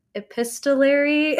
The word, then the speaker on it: epistolary: They said, I don't know how to say this word, epistolary.